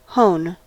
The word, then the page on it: hone: American English